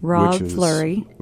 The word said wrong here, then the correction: Fleur de Lis: Well, it's uh, Fleur de Lis Forge, actually.